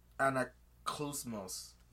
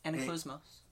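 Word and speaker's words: Anaklusmos: Chiron was like, oh, it's called Anaclusmos. – Yeah. Anaclusmos.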